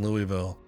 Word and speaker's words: Louisville: Hey, when I had my learner's permit to drive, one of the first times I ever drove was literally through downtown Louisville.